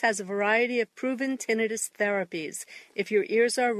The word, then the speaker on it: tinnitus: It's your tinnitus, actually pronounced tinnitus.